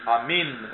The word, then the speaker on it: Amen: Therefore, you can say that if that is the case, Amen, or actually Amin, as it is sometimes pronounced in the East, because here we again are having the problem.